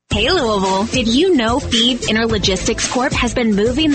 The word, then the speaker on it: Fives: Thebes is spelled F-I-V-E-S, like the number, but pronounced Thebes.